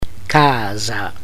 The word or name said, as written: casa